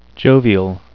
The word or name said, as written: jovial